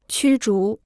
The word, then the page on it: 驱逐: qū zhú